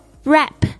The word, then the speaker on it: wrap: 好， wrap w r WAP 特别注意一下 W 不发音 WR 这样的形式，通常 W 都不发音，就像我们写字的这个写是 WRITE 对不对，是 write W 也不发音。